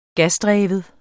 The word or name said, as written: gasdreven